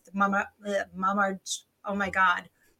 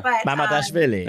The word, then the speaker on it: Mamardashvili: You know, maybe that'll work out for Inter with Mamardashvili. – Easy for you to say. – We're going to get a pronunciation. – Go. – Mamardashvili.